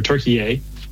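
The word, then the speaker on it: Türkiye: and just learn a ton about Turkey, or Turkey-A. As the proper pronunciation.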